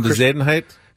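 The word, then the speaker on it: Bezuidenhout: How do you say it? – Christian Bezadenhout.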